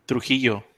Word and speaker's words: Trujillo: How'd you pronounce it? – Trujillo. – Trujillo, awesome, yeah.